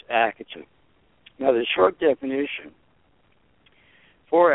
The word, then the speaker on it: Akatha: A-K-A-T-H-A. The T-H is pronounced like a hard T in higher Sanskrit. There's no TH the sound in that language. Therefore, the pronunciation is a hard T or ACRITA.